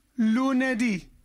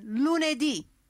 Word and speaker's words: lunedì: Lunedì. – Lunedì. – So now your turn. Lunedì.